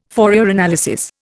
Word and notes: Fourier analysis: Indian

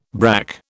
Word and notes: brack: British English

